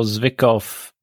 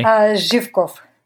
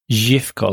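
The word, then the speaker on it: Zhivkov (Живков): Okay, so you were 12 when Todor Zhivkov resigned, and I've probably pronounced that really poorly. – Zhivkov. – Zhivkov. – Yes.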